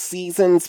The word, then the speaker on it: SZNZ: It's pronounced Seasons, but it's spelled S-Z-N-Z or S-Z-N-Z. You know, gotta give it both ways for the listeners, which is just an insane way to spell it.